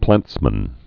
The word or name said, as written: plantsmen